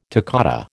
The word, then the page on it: toccata: American English